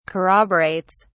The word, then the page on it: corroborates: British English